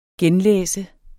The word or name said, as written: genlæse